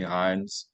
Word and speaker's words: Hynes: My name, Tony Hynes.